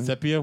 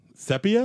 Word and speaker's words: sepia: Sepia? – Sepia? Well, how do you pronounce sepia? – That? Sepia? – That brownish tone? – Yeah. It's sepia. The brown tone.